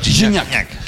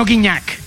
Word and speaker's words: Gignac: Así se pronuncia, ¿verdad? – Gignac. Que no Gignac.